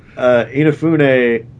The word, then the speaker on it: Inafune: Inafune, the creator of Mega Man.